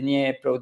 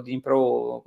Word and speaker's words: Dnipro: di Dniepro, Dnipro,